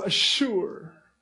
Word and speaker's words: Azure: Oh, Azure.